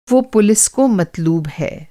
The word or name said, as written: मत्लूब